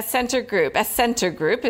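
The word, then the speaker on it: Scentre Group: Now, Center Group is how you say it properly, SCG for Till.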